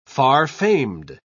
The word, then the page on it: far-famed: British English